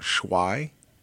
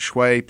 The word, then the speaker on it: swai: And sway is S-W-A-I. – I'd have never guessed that. – Yeah, sway. It's, I believe in Vietnam where this fish comes from, it's actually pronounced shway. Mm-hmm. But here on the Gulf Coast, we got our own thing. We call it shway.